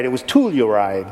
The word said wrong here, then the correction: Telluride: And he's like, no, it's Telluride.